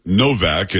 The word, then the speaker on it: Nowak: Now, again, it's spelled Nowak, but she says there. They most probably pronounce it Novak, and she's from that area, so she would know better than I.